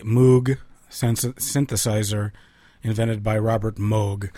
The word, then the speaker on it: Moog: His name is often mispronounced Moog, and as a testament to that fact, I'm going to play for you now a song off of the Mike Melvoin album, The Plastic Cow Goes Moog. Even though Robert Moog pronounced his name Moog.